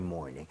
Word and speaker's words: morning: Every morning. 네. 이왕이면 morning 할 때도 morning보다는 R 좀 땡겨서 more 할 때 R, 혀를 많이 당겨서 발음을 해보도록 하겠습니다. 오케이?